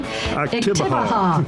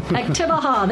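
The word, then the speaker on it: Oktibbeha: We've got a little bit of information from another library system, the Starkville Iktapa. I always say that wrong. How do you pronounce the county that this Starkville's in? – Iktibaha. – Iktibaha. There you go.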